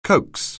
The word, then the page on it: coax: anglais